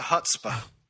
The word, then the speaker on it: chutzpah: Old Modest has, lots of chutzpah. That's how you say it.